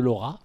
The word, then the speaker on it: Laura: Plutôt que de diffuser des images de Zangief, ils ont diffusé des images de Laura, puisque on va le prononcer comme ça. – On va dire Laura.